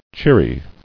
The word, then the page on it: Cheeriness: Cheer"i*ness